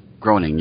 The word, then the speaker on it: Groening: I think it's Matt Groening. – Groening, yeah.